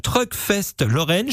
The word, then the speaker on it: Truck Fest Lorraine: Retrouvez d'ailleurs plus d'infos via la page Truck Fest Lorraine.